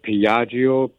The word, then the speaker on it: Piaggio: And if I pronounce this right, Piaggio, P-I-A-G-G-I-O.